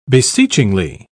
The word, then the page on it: beseechingly: British English